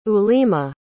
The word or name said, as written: ulima